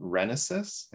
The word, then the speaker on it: Renisis: Her project is called Renisis. I hope I pronounced that correctly. – That's right. Yes.